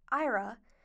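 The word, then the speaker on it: Éire: From Guinness to leprechauns to Oscar Wilde, Ireland, or as it's properly pronounced in Gaelic, Ira, has many iconic hallmarks to offer any tourist.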